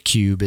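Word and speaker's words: Kub: you call it the Cube, is that the term? – Yeah, that's the Russian pronunciation.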